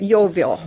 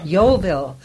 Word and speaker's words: Yeovil: You mentioned once before we came on the mic here something about the Yeovil colony. Am I pronouncing that right? – Yeovil. – Yeovil. – Yes. – Well, it's good you have me corrected here.